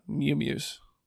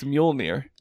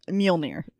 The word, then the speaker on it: Mjolnir: Mew-mews. – Is that how you pronounce it? – That's Mjolnir. – Mjolnir.